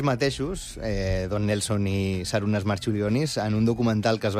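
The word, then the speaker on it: Marčiulionis: Jo crec que li diuen Marciulionis, però... Perquè aquesta C es pronuncia com una TX.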